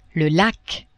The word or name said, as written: lac